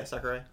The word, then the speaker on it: Sakurai: But they also have, so besides Sakurai, and I think I pronounced that wrong. – No, yeah, Sakurai.